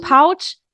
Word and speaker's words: Pouch: Fast, ganz fast. Pouch. – Das ist ja nicht mal in der Nähe von richtig. – Doch, es gibt auch Leute, die sagen das noch ganz anders. – Okay, und daher ist okay, es wird Pouch, P-O-U-C-H wird es geschrieben.